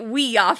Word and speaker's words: Ouija: It's for we and ya, it's French and German. – So wouldn't it be yes we ya? Yes. Well, if you're in the original pronunciation, yeah but it's not. It's a Ouija board.